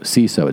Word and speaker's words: Seeso: CISO. It's spelled S-E-E-S-O.